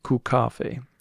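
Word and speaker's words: kokkaffe: And yeah, sure enough, the pronunciation is Kukafe. There's a real emphasis on the ku.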